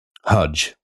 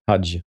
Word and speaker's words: El-Haj: Ja, men hur uttalas det då? – Haj. – Haj. – Jag tror det i alla fall.